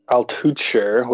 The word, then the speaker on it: Altucher: Since I started off talking about Altucher, which I said I'm probably butchering, so there you go. Mm-hmm. – Probably rhymes with butcher.